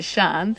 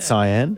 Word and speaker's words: Cyan: That's how you say it, Sean. – Cyan. It could be Cyan. It's a colour, isn't it? Cyan. – Yeah. – C-Y-A-N. – Yeah, C-Y-A-N.